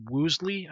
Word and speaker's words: Woolsey: and CIA Director James Woolsey, I believe it's pronounced, W-O-O-L-S-E-Y, I think it's Woolsey, that's what it is, I'm sorry, to launch a CIA investigation, and all of them were to report to President Clinton if they found anything.